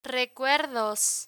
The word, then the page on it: recuerdos: Spanish